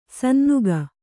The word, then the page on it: ಸನ್ನುಗ: Kannada